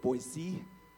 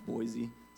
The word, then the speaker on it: Boise: They say it Boise, not Boise, so make sure you say it right.